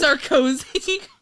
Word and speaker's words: Sarkozy: Sarkozy.